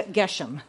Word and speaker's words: Geshem: So the enemies, you're wondering how to pronounce those names, right? Sanballat, Tobiah, Geshem. Those are how to pronounce the three names.